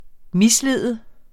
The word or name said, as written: mislede